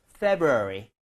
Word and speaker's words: February: 저는 추천하는 게 원래는 이제 February를 하는 게 맞는데 걔를 빠르게 하다 보면 앞에 R 발음이 굉장히 약화돼요. 그래서 저의 추천은 앞에 R을 좀 무시하듯이 슥 지나가는 것도 나쁘진 않다입니다. 그래서 거의 February가 정도가 될 정도로 February인데 원래 정상 발음은 February를 빠르게 하는 게 맞기는 해요. 제가 빠르게 해서 February.